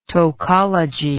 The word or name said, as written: tokology